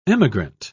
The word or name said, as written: emigrant